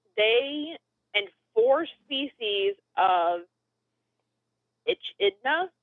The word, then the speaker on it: echidna: Echidna. – I looked them up. – Echidna. So the CH is a K sound.